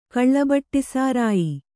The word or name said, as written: ಕಳ್ಳಬಟ್ಟಿಸಾರಾಯಿ